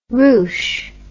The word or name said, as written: rouche